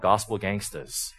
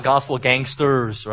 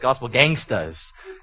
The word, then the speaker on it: Gospel Gangstas: And so one of my favorites is a group called Gospel Gangstas, right? and you got to say it like that. It's not Gospel Gangsters, right? Because, you know, from the hood, right? So it's Gospel Gangstas.